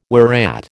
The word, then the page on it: whereat: American English